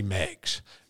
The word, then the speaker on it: Meigs: Meigs, spelled M-E-I-G-S, pronounced Meigs.